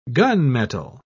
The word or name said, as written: gunmetal